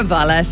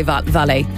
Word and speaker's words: valet: We're getting a bit of stick on WhatsApp for pronouncing a valet as a valet or a valet as a valet. – Mm-hmm. – Going, girls, you don't pronounce the T in valet.